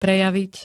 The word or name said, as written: prejaviť